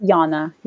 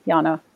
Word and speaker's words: Jana: It's Yana. Yana. Yana. It's, it's, okay. it's, spelled with a J, but it's pronounced Yana.